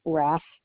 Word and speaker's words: Raff: Margo Raff. – Okay. Can you spell it, please? – M-A-R-G-O, and then my last name is Raff, R-A-F-F, as in Frank, Frank.